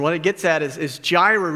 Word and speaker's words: Jireh: It's the word Jireh. That's how it's pronounced, for those of you that had questions on how to say it.